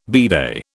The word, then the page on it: bidet: British English